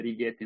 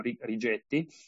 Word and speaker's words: Rigetti: E ovviamente ci sono i grandi titani di internet, probabilmente non conoscete di Wave e Righetti, che è americana nonostante il nome, appunto si legge Righetti, non Rigetti. Il nome un po' infelice se detto un po' in italiano, ma ci sono anche Google, IBM, Microsoft e Amazon.